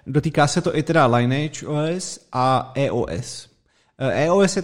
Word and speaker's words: Lineage: Já si myslím, že to asi Lineage se to vyslovuje.